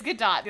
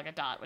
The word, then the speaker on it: Gadot: It's Gal Gadot, which is weird. – Yeah. I'm sorry. – It might be Gadot, but it's you pronounce the T.